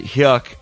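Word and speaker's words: Huyck: H-Y-U-Y-C-K. I pronounce it Hyuk because it rhymes with duck.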